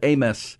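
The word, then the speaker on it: Abmas: It's A-B-M-A-S is how his name is spelled, but it's A-Y. – Mm-hmm. – It's Amos is how you pronounce his name.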